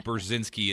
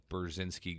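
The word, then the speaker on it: Burzynski: Again, Brzezinski, it's B-U-R-Z-Y-N-S-K-I, Brzezinski Group.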